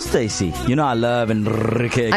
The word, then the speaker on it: Enrique Iglesias: Enrique Iglesias, be with you on East Coast Radio. That's how you say it, Jay Spoon.